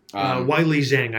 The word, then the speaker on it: Weili Zhang: We might split here on this on the next fight, the women's strawweight fight between Tisha Torres and Vel Zhang. If that's how you say her name. – Wiley Zhang, I believe.